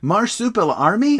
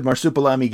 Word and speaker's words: Marsupilami: so marsupial army. – How's that? That's not how you say it.